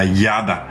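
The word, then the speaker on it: yada (ידע): Y en este caso, la palabra conociendo o conocer en hebreo es la traducción, digamos, con la pronunciación de nosotros sería yada, que significa así conocer, pero esta raíz, esta parte de la palabra en conocer tiene varios significados.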